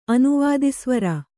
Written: ಅನುವಾದಿಸ್ವರ

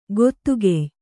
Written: ಗೊತ್ತುಗೆಯ್

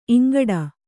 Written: ಇಂಗೂಟ

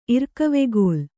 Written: ಇರ್ಕವೆಗೋಲ್

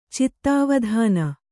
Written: ಚಿತ್ತಾವಧಾನ

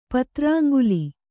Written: ಪತ್ರಾಂಗುಲಿ

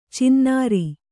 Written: ಚಿನ್ನಾರಿ

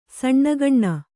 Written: ಸಣ್ಣಗಣ್ಣ

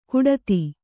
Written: ಕುಡಿತೆ

